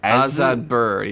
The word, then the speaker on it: Azetbur: We're introduced to his daughter, whose name I can't pronounce. – What is it? It's like It's Azetbur? – Azetbur, yeah. That's what it is.